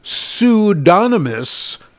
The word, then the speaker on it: pseudonymous: Pseudonymous. Well, you like that? We had to look up how to pronounce that. Because we kept thinking it should be, what did you say, Chris? – Pseudonym. – I don't know. But yeah, pseudonymous, yeah.